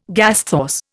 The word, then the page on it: gasthaus: Indian